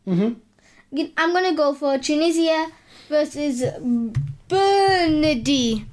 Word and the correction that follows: Burundi: I'm going to try and guess what these are from how you say it. Say it again. Bernardi. – Yeah. – Benidorm? – Bernardi. – Give me a look. Tunisia versus Burundi. – Oh, Burundi.